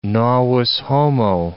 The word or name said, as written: novus homo